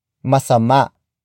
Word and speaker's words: masama: Word for word it's masama sa katawan ang asukal.